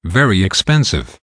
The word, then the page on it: very expensive: American English